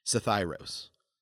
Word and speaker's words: Psithyros: Sathyros. It's spelled P-S-I-T-H-Y-R-O-S.